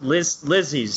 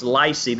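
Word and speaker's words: Lisey: I think it's pronounced Lizzie's story. – Lizzie's story? – I think so. I don't think it's Lycy. That would be a totally different story.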